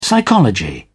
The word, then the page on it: psychology: British English